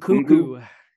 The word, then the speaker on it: Nkunku: Nkunku.